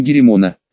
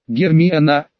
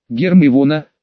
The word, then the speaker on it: Гермиона: Он звал ее Геремона. «Гермиона», медленно, по слогам произнесла Гермиона. «Гермиона», повторил Крам. «Гораздо лучше», похвалила Гермиона, заметила, что я на нее смотрю, и улыбнулась.